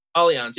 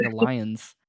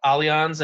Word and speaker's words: Allianz: I thought it was like Alliance. – Alliance? No, it looks like it, but it's pronounced Allianz, and it is a German company.